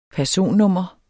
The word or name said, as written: personnummer